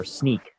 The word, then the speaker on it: Snyk: What I do, I am a developer advocate and engineer for Snyk, and you pronounced it correctly.